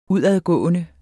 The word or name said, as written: udadgående